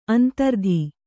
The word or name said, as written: ಅಂತರಿತೆ